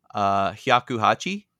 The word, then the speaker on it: Hyakuhachi: Yeah, so 108 in Japanese is read Hyakuhachi, which rhymes with Shakuhachi, which is traditionally a recorder-like instrument, which is also slang for blowjob.